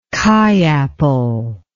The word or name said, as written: kei apple